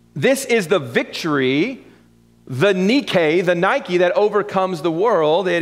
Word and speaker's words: Nike: Now, the word that we see here translated victory in the second part of verse number 4 is, in the English language, it's based off of the Greek word Nike, or properly pronounced Nike. I don't know if you knew that. That's what it's supposed to be.